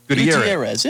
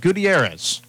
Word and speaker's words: Gutierrez: Vladimir, how'd you pronounce his last name? – Gutierrez. And you take Spanish Gutierrez. In college? Come on, man. – I did. Gutierrez. But you pronounce it, but I mispronounced it the first time around.